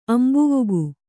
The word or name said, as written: ಅಂಬುವುಗು